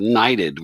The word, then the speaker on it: Knighted: So let's talk about your current book, which is called Knighted with the silent K on it.